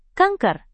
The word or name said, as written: kanker